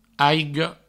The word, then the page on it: aiga: aigo